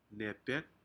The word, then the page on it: nepek: Aneityum